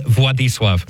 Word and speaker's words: Władysław: And he takes the name, and I'd love to hear you pronounce this one, Władysław. Can you get that one correct from that spelling? – It looks like Władysław. – Yeah, it's what it looks like. – It's Władysław.